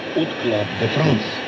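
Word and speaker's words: Hot Club de France: В 1932 году Юг основал от Club de France. Первое H французы не произносят, отсюда и фонетическое О.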